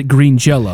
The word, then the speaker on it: Green Jelly: But then they had to change their name to Green Jelly, but they still pronounced it Green Jello.